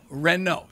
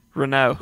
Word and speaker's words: Renault: Ren, like Frank Ren, the former Braves GM, and no, Renault, say it. – Renault.